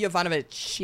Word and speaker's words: Yovanovitch: ambassador to Ukraine, Marie Yovanovitch.